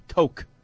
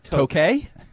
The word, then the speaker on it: toque: Tote? – Toque. – Oh, T-O-C-Q-U-E. – Oh, yes. Oh. Yes, that's right. – Toque? As in croquet? – It's pronounced toque.